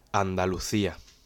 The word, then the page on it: Andalusia: Spanish